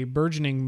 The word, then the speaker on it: burgeoning: I live in Minnesota, and at least anecdotally, there seems to be a burgeoning move. Is that how you pronounce that word? Burgeoning? That's how I would pronounce it.